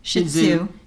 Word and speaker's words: Shih Tzu: Shih Tzu. Shih Tzu. Like shit on Sue.